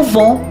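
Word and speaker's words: von (よん): Essa semelhança faz com que as pessoas prefiram pronunciar o número 4 como von para fazer uma distinção clara.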